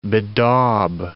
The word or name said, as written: bedaub